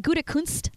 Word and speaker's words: Gutekunst: Brian Gutekunst. Okay. Did I say that right? Brian Gutekunst, the GM. – You got it. You nailed it.